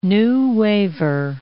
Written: new waver

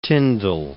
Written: Tyndall

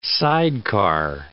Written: sidecar

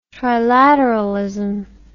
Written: trilateralism

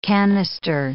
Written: canister

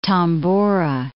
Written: Tambora

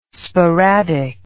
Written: sporadic

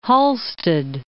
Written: Halsted

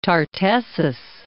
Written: Tartessos

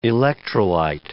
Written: electrolyte

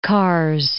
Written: Kars

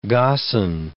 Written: gossan

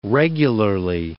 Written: regularly

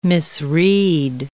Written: misread

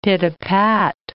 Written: pit-a-pat

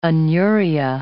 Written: anuria

